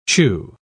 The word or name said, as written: chew